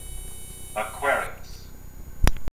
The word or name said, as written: Aquarius